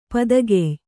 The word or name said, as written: ಪದಗೆಯ್